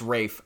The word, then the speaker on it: Ralph: It's Rafe. – I thought that his name was Ralph and he went by Ray, but I may be wrong. – It's Rafe Fiennes.